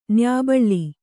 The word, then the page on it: ನಾಯ್ಬೇಲ: nāybēla